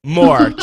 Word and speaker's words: Mohr: Mohr joining us today.